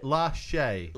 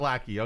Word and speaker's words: Lachey: Lachey. – It's not Lackey. Okay.